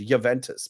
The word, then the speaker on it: Juventus: Anytime I've any ever heard a player or a sportscaster talk about Juventus, it's pronounced with a Y rather than H. It's Juventus.